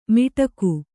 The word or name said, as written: ಮಿಟಕು